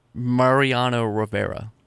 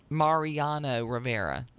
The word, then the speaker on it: Mariano Rivera: River is referencing the Marino Riviera, former, but did I say that right? Mariano Riviera, a closing picture for the New York Yankees. – Mariano Rivera. – Mariano Rivera.